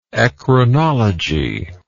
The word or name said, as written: eccrinology